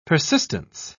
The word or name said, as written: persistence